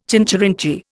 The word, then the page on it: chincherinchee: Indian